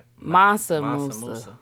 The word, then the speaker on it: Mansa Musa: And it's pronounced Mansa Musa.